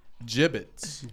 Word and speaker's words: Jibbitz: It's spelled J-I-B-B-I-T-Z. Gibbets. Gibbets.